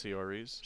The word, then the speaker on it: Saoirse: Saoirse Ronan in Little Women. – I think it's Saoirse. – Sorry. Yes. That is such a hard name.